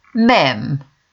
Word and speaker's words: Ma'am: No selfies allowed, and the couple should be addressed as Your Royal Highness the first time, then after that, Sir or Ma'am, which rhymes with jam.